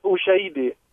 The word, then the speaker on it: Ushahidi: It's pronounced Ushahidi, and what it really means is the witnessing.